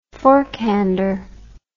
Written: forkhander

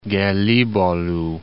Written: Gelibolu

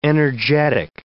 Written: energetic

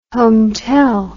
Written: hometel

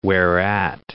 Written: whereat

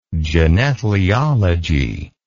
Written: genethlialogy

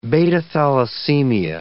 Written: beta-thalassemia

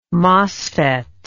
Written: mosfet

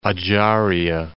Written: Ajaria

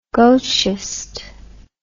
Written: gauchiste

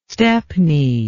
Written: stepney

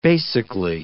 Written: basically

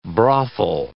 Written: brothel

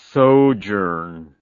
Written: sojourn

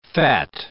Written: phat

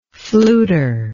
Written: fluter

